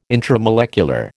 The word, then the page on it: intramolecular: American English